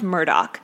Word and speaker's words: Murdaugh: It's spelled Murdaugh, like Daugh, but it's pronounced Murdoch.